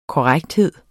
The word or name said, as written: korrekthed